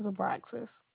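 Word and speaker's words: Abraxis: My name is Abraxas.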